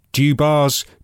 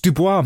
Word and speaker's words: Dubois: They all just keep calling her Dubars, Dubois. It's Dubois, mate.